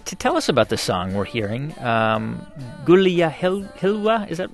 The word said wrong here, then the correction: Gulia Hilu: am I pronouncing that right? – Gulia Hilu. This is a folk song from Iraq.